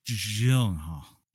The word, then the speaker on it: Gyllenhaal: Gyllenhaal. – Well, I say you can't say his name without sounding drunk. It's Jake Gyllenhaal.